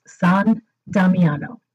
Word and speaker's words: San Damiano: It's actually Italian, and it's pronounced San Damiano. So that's San for Saint, and Damiano, which is Damien, which is the church where St. Francis heard God speaking to him from the crucifix.